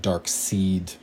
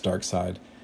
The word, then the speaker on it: Darkseid: And there's a, a, villain called Darkseid, it's sort of spelt like Dark Seed, but it's uh, pronounced Dark Side.